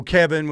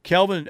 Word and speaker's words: Kelvin: Steely, do you remember how mad Al used to get when people said Kelvin Sampson's name wrong? – Oh, Kevin, when they call him Kevin, I think it was. Yes. Yes, I do remember that. Kelvin, hey, by the way, they massacred Tulsa last night.